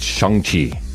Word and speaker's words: Shang-Chi: It's Shang-Chi. I know it's S H A, and normally you would say that would be Shang because of the phonetic pronunciation. It's okay. Shang-Chi.